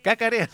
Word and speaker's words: cacarean: Sí, porque son de gallinas felices que caraquean todo el día. ¿Cómo se dice? Caraquean, ¿no? ¿O no? Cacarean. Ahí está. Cacarean.